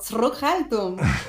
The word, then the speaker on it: Zurückhaltung: decirlo de nuevo, Zurückhaltung. – Perfecto, con la pronuncia perfecta.